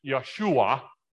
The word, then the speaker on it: Yeshua: In Hebrew, it sounds more like Yeshua.